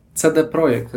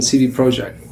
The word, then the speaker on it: CD Projekt: I'm the co-founder and joint CEO of CD Projekt, as you properly pronounce it, in Polish, or the more international version, CD Projekt.